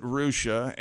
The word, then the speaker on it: Russia: Fort Recovery is by R-U-S-S-I-A. – Is that where the rehab is? – You pronounce it Rusha, and there's a Houston there, which is spelled H-O-U-S-T-O-N.